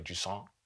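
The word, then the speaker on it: Jusant: And it is a story-driven game, as, you know, we just played Jusong. I said it right this time. – You did.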